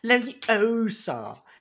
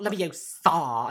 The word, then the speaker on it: Leviosa: But yeah, so Hermione is just like, you're saying it wrong. It's Leviosa. Not Leviosa.